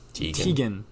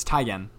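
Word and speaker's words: Teigen: Chrissy, however you pronounce her last name, it's Tegan. Or no, sorry, it's Tygan. Tygan, not Tegan.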